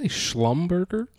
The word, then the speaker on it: Schlumberger: Did you say Schlumberger? – Yeah, something like that. S-C-H-L-U-M-B-E-R-G-E-R. – I think this time we actually think you pronounced it right.